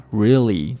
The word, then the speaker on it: really: 下面一句话 really really，我听到非常多同学会把 really 读成 really， what 啊，是 really 不是 really really really，前面是一个长元音 e，后面也是一个长元音 e， really really really。